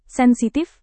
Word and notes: sensitif: indonesian

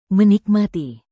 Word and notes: menikmati: Indonesian

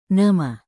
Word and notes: nama: indonesian

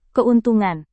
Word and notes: keuntungan: indonesian

